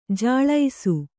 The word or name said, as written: ಜಲಸ